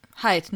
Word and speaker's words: Hyde: Ähm, und dabei, äh, also wie, wie spricht man den denn aus? Hyde oder Hide? Weiß ich nicht. – Also Hyde der. – Hyde, ne? – Ja.